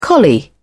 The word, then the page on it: collie: British English